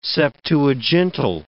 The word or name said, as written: Septuagintal